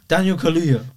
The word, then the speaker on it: Kaluuya: How do you say his name? – Daniel Kaluuya. – There you go. Not the way you were saying it before, but yeah.